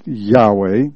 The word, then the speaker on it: Yahweh: Y-H-W-H, actually. Pronounced Yahweh.